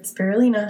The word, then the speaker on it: spirulina: So number one is spirulina. Did I pronounce that right? – Spirulina. – Spirulina, yes.